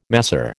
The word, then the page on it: masseur: American English